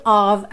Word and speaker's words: of: Step of the way, step of the way, outro of aí, OF com som de OV.